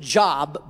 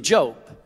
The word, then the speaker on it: Job: Those of you that are new to your Bibles, you may pronounce it Job, but it's really Job.